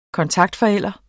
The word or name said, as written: kontaktforælder